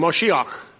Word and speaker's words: Moshiach: That word Messiah, by the way, in the Hebrew, is pronounced almost the same, Moshiach. And I can't pronounce Hebrew very well, but that's about what it says.